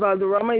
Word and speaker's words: Valderrama: Madeline Valderrama. – Okay, how do you spell that, ma'am? – Madeline. And Valderrama is VSM Victor AL.